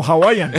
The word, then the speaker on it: Hawaiian: Hawaiian Pizza Day. – No, Hawaiian. Hawaiian. Al principio lo dijo bien.